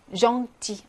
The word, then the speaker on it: gentil: gentil, 앙 발음이죠.